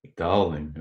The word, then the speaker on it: Dowling: So, okay, before I say your last name, I'm going to preface it by I'm from Bermuda, and in Bermuda, we pronounce it doling. In Canada, they pronounce it dowling, and that's where I currently live. So, how do you pronounce it in the UK? – Yeah, it's an Irish uh, name. I'm from Ireland, uh, but it's the Canadian pronunciation.